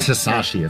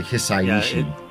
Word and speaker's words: Hisaishi: It's not just Hisashi, it's like Hisaishi. – Yeah, because Japanese is just pronounced differently.